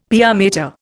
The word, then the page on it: pia mater: Indian